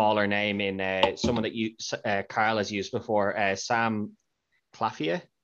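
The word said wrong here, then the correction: Claflin: I think is how you say his name. – Claffin, is it? – Claffin, sorry. Sam Claffin, sorry.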